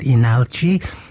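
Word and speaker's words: Leenalchi: They were called Inalchi, or although it's spelled Linalchi.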